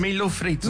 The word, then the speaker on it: milho frito: Milho frites. No. Am I saying it right? – Close. Yeah, sure. – How do I say it? – Milho frito.